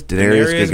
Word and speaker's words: Daenerys: Daenerys Targaryen. – Okay, so she's gonna try and kill Jon Snow. – Why? – Okay, spoiler alert. Fast forward 2 minutes. 1, 2, 3. Okay, so Daenerys Targaryen is his aunt.